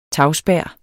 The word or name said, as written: tagspær